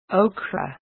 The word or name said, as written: okra